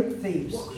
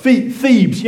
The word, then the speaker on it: Thebes: Is that how you say it? Tebes? I don't know. Someone will tell me. In Egypt. Anyone been there? It's where the tomb of Tutankhamun is. Sorry? – Thebes. – Thebes.